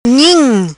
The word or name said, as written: Nhim